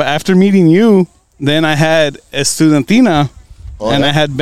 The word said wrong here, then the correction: Estudiantina: No, you're not pronouncing it. – One more time. – Estudiantina. Estudantina. – We're missing an I in there somewhere.